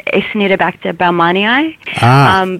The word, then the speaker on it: Acinetobacter baumannii: A. bulmani. – Well, with the Australian accent, the way that we say it is Acinetobacter baumannii.